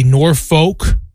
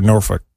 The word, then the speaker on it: Norfolk: Same thing, like I remember a lot of people would say Norfolk because it's like N-O-R-F-O-L-K. – Yes. – And it's Norfolk.